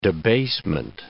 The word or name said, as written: debasement